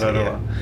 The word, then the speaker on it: Te Araroa: Is that how you pronounce it? – Yeah, that's how you pronounce it. Te Araroa.